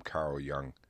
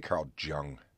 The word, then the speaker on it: Jung: This one's from Carl Jung. I always want to say Carl Jung, just the way I see the way his name is spelled, but it's Carl Jung.